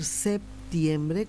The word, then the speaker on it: septiembre: Y la Real Academia Española, el diccionario y la academia nos dicen que se puede decir de manera indistinta, que lo correcto es decir septiembre, pronunciando la P, pero que en esta parte del continente, de la parte sur de Sudamérica, específicamente en Paraguay, solemos no pronunciar la P, y que también entonces aceptan esa forma de pronunciar el nombre del presente mes.